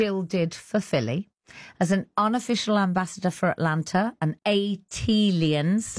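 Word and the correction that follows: ATLiens: That is pronounced A-T-aliens. – Thank you. – Like Atlanta alien, A-T-alien.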